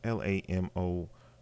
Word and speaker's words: Lamorne: Lamar Morris and Issa Gonzalez, they were huge surprises for me. I've seen them both. I know, I hope I'm pronouncing it correctly. It's Lamorne.